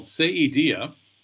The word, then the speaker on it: Saidia: It's called Saidia.